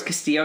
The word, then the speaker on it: Castillo: It's been established in the show as Castillo by literally every other character. – And that's not just English people saying the name wrong. It's actually double L is more like a.